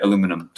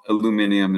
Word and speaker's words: aluminium: Aluminum toxicity in the brain. For those that don't know, aluminium is how you say aluminum on the other side of the world.